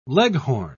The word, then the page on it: leghorn: British English